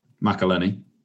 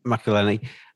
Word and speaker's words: McElhenney: McElhenney. McElhenney.